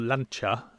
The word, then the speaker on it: Lancia: I did read, actually, Lancia, or Lancia, as I believe we're meant to pronounce it, have started hinting at the fact that they are going to resurrect the Delta as an electric car, which could be interesting.